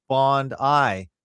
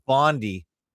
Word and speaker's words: Bondi: Also, I should point out that we pronounce Bondi like Bond Eye, not Bondi, as I hear most people in North America and or Canada pronounce it. It's amusing for us to hear Americans say Barndy.